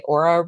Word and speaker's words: Oura: I can't remember if we've talked about on the show my Aura ring that I wear. – No. – It's spelled Oura.